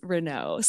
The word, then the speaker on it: Renault: Usually, it's the first part of my name that people stumble stumble on uh, because it's spelt a little differently, but it's Renault. So you were very close.